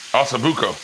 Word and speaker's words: ossobuco: Hasta the Boco. How you say it again? – Ossobuco. – Ossobuco.